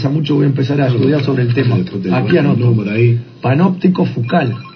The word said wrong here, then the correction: Foucault: No sé cómo se dice. – Foucault. Está bien. Foucault.